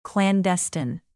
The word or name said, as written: clandestine